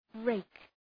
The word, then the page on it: rake: reık